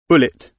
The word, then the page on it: bullet: British English